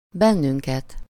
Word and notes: bennünket: /ˈbɛnːyŋkɛt/

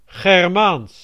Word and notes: germanique: France; /ʒɛʁ.ma.nik/

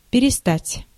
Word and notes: перестать: [pʲɪrʲɪsˈtatʲ]; perestat

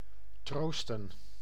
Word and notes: console: American English; /ˈkɑːn.soʊl/

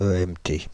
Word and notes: EMT: Île-de-France; /ə.ɛm.te/

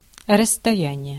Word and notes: length: American English; [lɛŋθ]

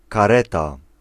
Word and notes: kareta: /kaˈrɛta/

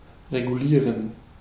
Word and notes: reglera: /regˈleːra/

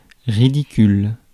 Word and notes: ridicule: France; /ʁi.di.kyl/

